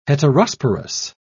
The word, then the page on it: heterosporous: British English